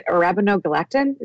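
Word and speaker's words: arabinogalactan: I've got to try this, because I actually prescribe acacia fiber and arabinogalactin? Is that how you say it? – Yeah.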